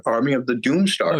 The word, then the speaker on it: Army of the Doomstar: Army of the Doomstar.